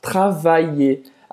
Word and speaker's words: travailler: Travailler. Aqui é como se fosse um i longo. Travailler. Travailler. – Ótima dica. E aí no final a gente não vai pronunciar o R travailler.